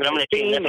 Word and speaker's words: Dominic Thiem: No, it's Dominic Thiem, I believe is how you announce his name. – Dominic Thiem, that's right.